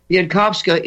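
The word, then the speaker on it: Bieńkowska: Biankowska is herself, you pronounce those W's as an F in Polish, Biankowska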